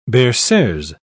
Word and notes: berceuse: British English

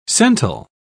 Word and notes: cental: British English

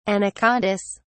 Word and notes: anacondas: British English

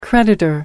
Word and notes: creditor: American English

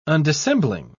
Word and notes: undissembling: British English

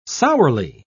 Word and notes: sourly: British English

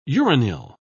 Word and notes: uranyl: British English

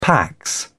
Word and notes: pax: British English

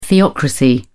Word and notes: theocracy: British English

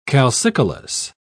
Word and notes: calcicolous: British English

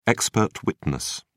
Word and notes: expert witness: British English

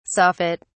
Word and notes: soffit: British English

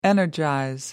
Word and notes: energize: American English

